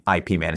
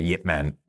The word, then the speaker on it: Yip Man: So, and yes, everyone, I'm jokingly saying IP Man instead of Yip Man, Yip Man, because they spell it the IP way.